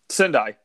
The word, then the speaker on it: Sendai: The IWGP Champion Series in, I'm going to butcher this, Sendai, Japan? – Sendai, yep.